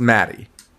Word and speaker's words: Mattei: But for all those motherfuckers, your wife's maiden name is spelled exactly the same. – Same way. Yeah. – And it's pronounced Maddie. – That's correct.